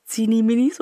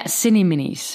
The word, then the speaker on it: Cini Minis: ja, ja, ja, Cini Minis. Die Cini Minis. Es wird, weißt du, American ausgesprochen. – Ja, wegen des Cinnamon.